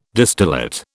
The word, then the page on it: distillate: British English